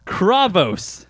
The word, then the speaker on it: Kravos: He says it how to say it. Kravos. So I was right, you jerk. – Bravos. – Oh, it's like Bravos in Game of Thrones.